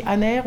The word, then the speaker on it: Asnères: Donc du coup, nous sommes à Aner. On dit Aner, on prononce le S à la fin ou pas Non, on ne prononce pas le S.